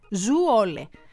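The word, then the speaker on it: Zwolle: ότι η πόλη προφέρεται Zuole.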